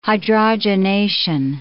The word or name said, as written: hydrogenation